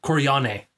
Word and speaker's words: Corleone: The correct Sicilian pronunciation... – Oh, Lord. – You have to do something. I thought you fixed it. I fixed it. – Would be Corleone. – Okay. – Because the L... There is no L in Sicilian for some reason. I think, like, the L is not pronounced that way. Like, it's pronounced a little bit differently.